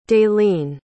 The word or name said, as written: dalene